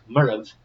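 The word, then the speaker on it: Moireabh: So Murray in Gaelic Murrav. So I tell you the Gaelic pronunciation because I actually think the Latin version of it, Moravia, if you take the IA off the end of it, is actually closer with that V on the end to the Gaelic pronunciation of Murrav.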